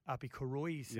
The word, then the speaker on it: Koroisau: He's a better 9 than Api Korosau. Or Uppy. – Api Koroisau.